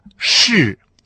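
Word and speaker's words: ши (是): Так вот, эта связка в китайском слова «ши». С каким тоном она произносится? Совершенно верно. Связка «ши» произносится с четвертым тоном.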